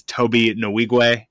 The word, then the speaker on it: Nwigwe: It is Toby Nwigwe.